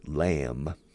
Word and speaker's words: lamb: roast lamb. Cordero asado, lamb. Fijaos, se escribe lamb con B al final, pero es B muda. Lamb, roast lamb.